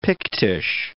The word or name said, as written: Pictish